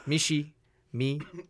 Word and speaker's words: Michiemee: M-I-C-H. – M-I-C-H-I-E. Okay, my bad. Michi. It's spelled Michi, but it's actually said Mishy Me. M-I-C-H-I-E-M-E-E.